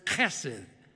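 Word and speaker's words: Chesed (חסד): The Hebrew word is chesed, C-H-E. The C-H is a hard, guttural C-H. You're saying it right when something lands on the neck of the person in front of you.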